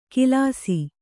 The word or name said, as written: ಕಿಲಾಸಿ